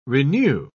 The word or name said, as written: renew